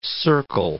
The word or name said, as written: circle